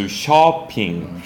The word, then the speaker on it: shopping: 쇼 아니고 쇼핑. – 그렇다고 샤핑은 아니, 야까지 하는 건 아니고요. – 예, 아까지는 아니고 어예요. 그래서 한국어로 어 쓰시면 딱 그 말입니다. So shopping.